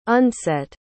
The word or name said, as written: undset